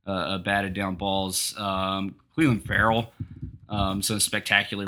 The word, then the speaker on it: Ferrell: wants his last name pronounced Farrell, spelled F-E-R-R-E-L-L, but pronounced like F-U-R-L.